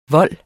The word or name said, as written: vold